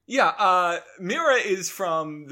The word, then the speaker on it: Mira: Yeah, like mirror, but it's saying with some sort of accent. Mira, there you go.